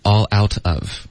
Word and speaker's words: all out of: I'm sorry, they were, 他们是什么呢 ,all out of, 这三个字的组合很常见 ,all out of, OK? 那这个说法呢我们会有一个比较独特的实际发音 ,all 的这个 l 的音要连读到 l,all out, 然后 out, 跟 of 也要连读，同时浊化，所以就变成 out of 三个字的组合是 all out of I'm sorry, they were all out of apple pie。